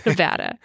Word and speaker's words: Nevada: Oh, wait. No, you didn't. It's Nevada. – Yeah, it's Nevada. – Yeah, we got to get that right for his... – Yeah. – Nevada.